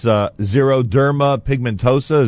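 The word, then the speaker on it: xeroderma pigmentosum: I explained, or at least I tried to pronounce, xeroderma pigmentosa. Is that the correct pronunciation? – Correct.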